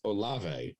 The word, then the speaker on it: Olave: Chris Olave, apparently, is how it's pronounced, uh, goes to the Saints.